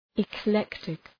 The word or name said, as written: eclectic